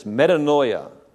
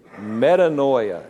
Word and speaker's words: metanoia: The word is a Greek word pronounced like this, metanoia. Say that with me, metanoia.